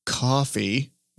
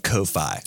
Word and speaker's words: Ko-fi: And so the service that we landed on is called Coffee. – But it looks like Ko-Fi. – It's spelled K-O-F-I.